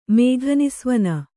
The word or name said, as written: ಮೇಘನಿಸ್ವನ